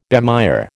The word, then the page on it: bemire: American English